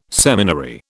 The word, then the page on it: seminary: British English